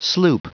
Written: sloop

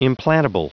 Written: implantable

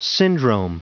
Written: syndrome

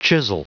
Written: chisel

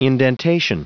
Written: indentation

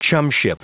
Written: chumship

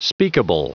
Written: speakable

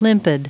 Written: limpid

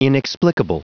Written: inexplicable

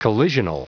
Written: collisional

